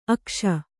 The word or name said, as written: ಅಗುಸೆ